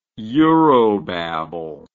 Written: eurobabble

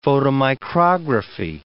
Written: photomicrography

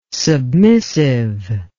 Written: submissive

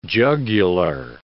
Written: jugular

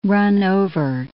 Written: run-over

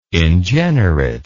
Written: ingenerate

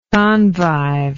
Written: convive

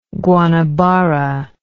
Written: Guanabara

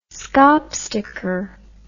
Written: schaapsteker

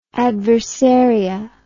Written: adversaria

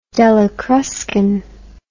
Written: della cruscan

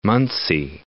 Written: Muncie

